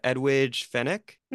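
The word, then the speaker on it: Edwige Fenech: I'm going to butcher the pronunciation, but Edwidge Fennec? – Yeah, no, that's right. – Oh, shit. – Edwidge Fennec.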